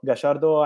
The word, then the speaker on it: Gallardo: Let me say my personal opinion, my friend, is about Gallardo. I try with Argentinian pronunciation of his name because I love this manager.